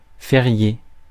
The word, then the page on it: férié: France; [fe.ʁje]